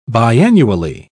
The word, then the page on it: biannually: British English